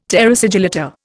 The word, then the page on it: terra sigillata: Indian